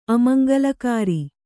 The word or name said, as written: ಅಮಂಗಳಕರ